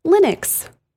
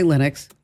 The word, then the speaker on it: Linux: I say it as Linux. What about you? – I say Linux. – Yeah. Not everybody does, because this is the number one. – Is it? – Mispronounced. Yes. I bet people say Linux. – Oh, yes. Or Linux. – Linux. Sure. – Like Kleenex. Linux.